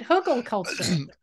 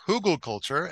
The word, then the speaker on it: Hugelkultur: Have I said that right? Hugelkultur. – I first heard it pronounced Hugelkultur, and that seems to be how I hear it the most.